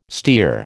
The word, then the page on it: stere: American English